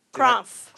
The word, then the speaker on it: Krompf: Your level class was where you met a lot of great people, and you formed a group, an independent team called Crumpt. – Crumpf. – Crumpt. – Crumpf. – How is that pronounced? – K-R-O-M-P-F. – And Crumpf.